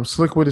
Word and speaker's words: Sliquid: um, Sliquid is spelled like liquid with an S at the beginning, and they have some amazing lubes.